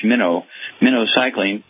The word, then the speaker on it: minocycline: But for the minocycline, you're going to have to get a prescription from your doctor for 6 to 8 weeks of minocycline, again, pronounced like the bait fish minocycline.